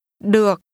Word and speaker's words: được: This is pronounced with a low, falling, broken tone. Say again, okay. – Được.